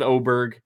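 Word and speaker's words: Åberg: It was Aberg, then it was Aberg, then it was Ober. Now it's back to Oberg. Uh, so I, I I can't. I'm done. There's been four pronunciations in four months. I'm sticking with the last one I heard that PJ Radio was just uh, saying all week long.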